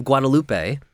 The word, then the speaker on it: Guadalupe: The feast of the Our Lady of Guadalupe, I believe I'm saying that right.